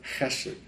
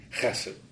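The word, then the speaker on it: chesed (חסד): It's the Hebrew word chesed. It's a guttural pronunciation, chesed.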